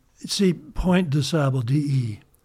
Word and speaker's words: de: But remember, in French, it's pronounced de, kind of a, you know, like de, you know.